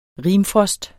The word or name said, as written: rimfrost